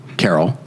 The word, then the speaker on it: Carell: I will mention, we had a family friend, named Mike Carroll, last name spelled Carell, but pronounced Carroll.